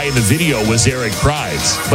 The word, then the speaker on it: Prydz: Is it Eric Prydz? – Yeah, I'm playing. It's Prydz. – P-R-Y-D-S.